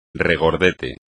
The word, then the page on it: regordete: espagnol